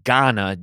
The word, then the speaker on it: Gaana: They have uh, uh, it's pronounced, I think it's pronounced Gaana, G A A N A, is a podcast platform that we're also on, and that's where it's being listened to.